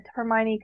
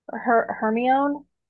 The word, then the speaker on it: Hermione: Like, she, like, people to this day still say that Hermione could be black because she never actually said she was white.